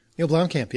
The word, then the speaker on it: Neil Blomkamp: I can't remember who it was, but someone on the Facebook page said, I can never pronounce it, but Neil Blomkamp? – Neil Blomkamp, yeah.